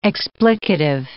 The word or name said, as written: explicative